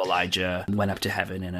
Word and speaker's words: Elijah: Oh no, it's one's an SH and one's a J. – Oh, like Elisha. – Yeah. Elisha and Elijah.